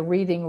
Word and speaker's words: Reading: And yes, it does look like the word reading, just for all of you who maybe thought you were using the Reading Railroad in Monopoly. It looks like reading, it's pronounced Reading, but there are several worldwide and even in the US, so make sure you do Pennsylvania.